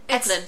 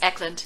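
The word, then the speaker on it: Ecklund: So it's Anna Eklund? Eklund? – Eklund. – It's E-C-K-L-U-N-D. – Let me look. I think it's Eklund.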